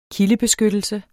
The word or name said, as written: kildebeskyttelse